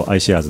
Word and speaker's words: iShares: iShares と書いてあるね、検索していただくと、 i はアルファベットの i ですけども、検索していただくと iShares の ETF のブランドがいくつも出てきますので、ぜひとも、こちらもねご覧いただければと思います。